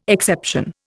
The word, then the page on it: exception: Indian